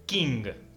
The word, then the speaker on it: Qing: En China gobernaba la dinastía Qing, que se escribe Qing con Q, Q-I-N-G, pero se pronuncia Qing.